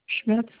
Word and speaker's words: Schmitz: N-N-I-S. – And last name? – Schmitz, S-C-H-M-I-T-Z. – Okay, I have S-C-H-M-I-T-Z. – Correct. – Okay. And Dennis spelled D-E-N-N-I-S.